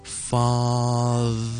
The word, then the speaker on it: father: The 跟住呢我哋读 father 如果再慢少少听住 father 係好似听落去有少少轻微嘅黐脷根嘅感觉嘅 father 其实 mother 亦都係同一个发音咁都係呢个 the ok